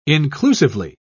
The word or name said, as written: inclusively